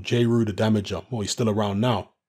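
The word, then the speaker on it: Jeru the Damaja: Just look up J.Ru the Damager. It's spelled J-E-R-U. And Damager is spelled D-A-M-A-J-A.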